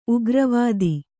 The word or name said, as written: ಉಗ್ರವಾದಿ